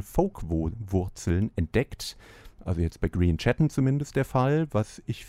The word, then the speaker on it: Grian Chatten: Green Chatten, immerhin ihn habe ich richtig ausgesprochen, den Titel allerdings glaube ich nicht, denn es ist Fairlease, nicht Fairlies.